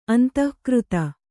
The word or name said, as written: ಅಂದಗಾರ್ತಿ